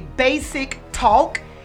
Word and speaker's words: Baysick Talk: Let me know if I'm pronouncing this correctly. Basic Talk. – That is absolutely correct.